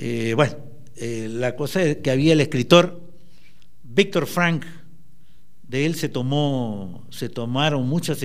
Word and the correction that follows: Frankl: Frankl se pronuncia, él dice: quien tiene una razón para vivir es capaz de soportar prácticamente cualquier cosa.